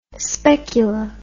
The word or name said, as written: specula